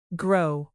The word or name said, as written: grow